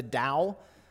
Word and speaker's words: Tao: Lewis has a concept that he refers to as the Tao. It's T-A-O, but it's pronounced with a D.